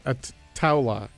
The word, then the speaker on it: Ataula: Ataula, you pronounce it like towel, Do not you?